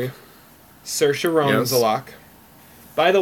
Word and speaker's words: Saoirse: And in her opening monologue, she said it's Saoirse like inertia. So there you go. It's Saoirse Ronan.